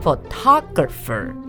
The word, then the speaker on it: photographer: Photographer 有一点点进步，可是重音还是要放在 photographer。 – 哦，原来是 photographer。